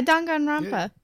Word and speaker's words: Danganronpa: Danganronpa? Is that how you pronounce it? I don't fucking remember. – Yeah, Danganronpa.